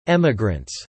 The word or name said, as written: emigrants